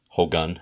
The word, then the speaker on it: Hogun: I say Hogan, well, Hogan, but it's pronounced, it's spelled H-O-G-U-N. Hogan, I guess, like Shogun.